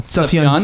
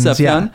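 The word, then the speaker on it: Sufjan: In reading that name, there's a J in there. I'm pretty sure it's like it's a soft J, like yogging. – It's Suffin Stevens. – Suffin? – Yeah.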